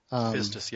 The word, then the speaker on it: pistis: It's pistis? – Pistis, yes.